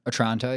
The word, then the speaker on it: Otranto: How would you pronounce that? Otranto? – Otranto, yeah. – Otranto. That okay. Yeah, that's better.